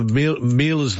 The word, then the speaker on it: Miele: I've heard from the Miele's themselves. In Australia, Miele rhymes with Sheila.